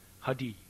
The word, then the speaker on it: Hare: You had a unique pronunciation of the mantra, especially the words Hare, which I always thought you pronounced Hadi.